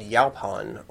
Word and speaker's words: yaupon: Um, but the one I wanted to talk about today is called Yaupon or Yaupon Holly.